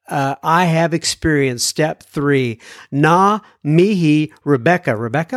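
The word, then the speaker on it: ngā: It's a NGA. I used to say nuga mihi, and I think she wrote me back and said the G is silent, if I am not mistaken.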